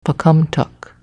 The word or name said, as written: Pocumtuck